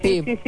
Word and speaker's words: Tivoli: Quiero decir, bueno, se pronuncia igual en italiano, ¿no? – Tivoli. Es una ciudad cerca de Roma.